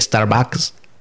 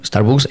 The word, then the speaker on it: Starbucks: Pero como lo pronuncié mal, no dije Starbucks. Lo pronuncié con U, Starbucks, entonces no me entendieron.